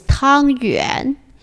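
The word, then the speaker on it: 湯圓: Chinese pronunciation is Tang Yuan.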